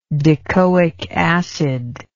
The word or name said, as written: decoic acid